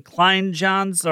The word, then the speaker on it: Kleinjans: I've, until this year, I've never heard people pronounce the J like that. I went to high school with people in his family. His cousin is a friend of mine, and we always said Kleinjans.